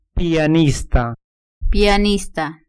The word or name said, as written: pianista